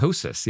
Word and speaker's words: ptosis: Ptosis. Yeah. Ptosis. Yeah. The P is silent. P-T-O-S-I-S. Ptosis.